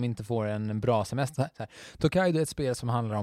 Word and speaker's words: Tokaido: Ett Tokado... Nej, Tokado. Tokaido? Tokaido! – To Tokaido tror jag det ska uttalas.